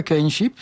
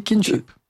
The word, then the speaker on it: kinship: Il précise qu'en anglais, le « i » de kinship, alors ça ne dit pas « kinship », ça dit « kinship ». Ça prononce comme un « i » français, comme « king ».